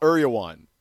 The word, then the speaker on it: Erewhon: E-R-E-W-H-O-N. Uriwan. – Right. It's so fancy that it's a hard thing to say. – I always pronounced it Erewhon or Eriwan. I think it's Eriwan. I could be wrong about that, but I'm almost positive.